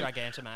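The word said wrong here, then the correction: Gigantomachia: Gigantamax. – Gigantamachia. – Yeah, Gigantamachia.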